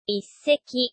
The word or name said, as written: isseki